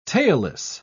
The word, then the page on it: tailless: British English